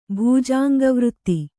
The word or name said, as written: ಭೂಜಾಂಗವೃತ್ತಿ